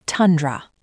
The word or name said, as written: tundra